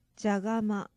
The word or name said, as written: jagama